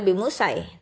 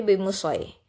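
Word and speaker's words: بمصيطر: Jadi bacanya tidak boleh bi musai, tapi bi musoi لست alaihim nah,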